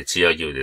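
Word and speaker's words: 千屋牛: グルメで言うと、岡山県のブランド牛といえば、チア牛ですかね。千の屋敷の屋に牛と書いてチア牛ですけど、こちらは新見市のブランド牛で、これはなかなか美味しいですね。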